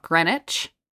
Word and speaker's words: Greenwich: Like, there's a city in Connecticut called Greenwich, but it's obviously it's spelled Greenwich.